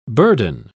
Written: burden

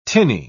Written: tinny

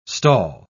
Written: stall

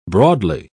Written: broadly

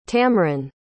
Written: tamryn

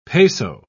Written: peso

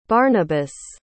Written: barnabas

